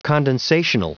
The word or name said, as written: condensational